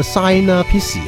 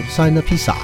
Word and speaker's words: Sinapsi: 系啊，原来系应该 Sina Pissi 咁样，S I N A P S I 咁样，或者 Sina Pizza 咁样啦吓。